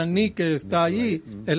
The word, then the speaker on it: nike: Una vez más, esa palabra victoria en el griego es nike, se escribe N-I-K-E, mal pronunciado en la cultura moderna como Nike, pero es Nike.